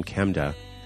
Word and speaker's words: Chemda: There's a search box in there for fundraisers, and just type Kemda in there, and that's spelled C-H-E-M-D-A. That's her name, Kemda.